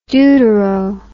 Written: deutero

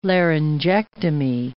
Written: laryngectomy

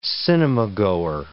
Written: cinemagoer

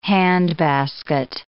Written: handbasket

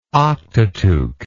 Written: octateuch

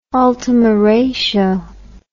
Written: ultima ratio

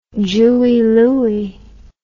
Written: jewie louie

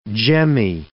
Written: gemmae

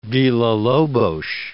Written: Villa-Lobos

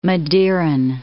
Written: Madeiran

